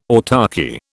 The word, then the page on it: autarchy: British English